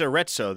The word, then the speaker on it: Arezzo: Yeah, it's Arezzo. There used to be when I was in journalism school at the illustrious University of Oklahoma, there was an exchange program every year that was in Arezzo.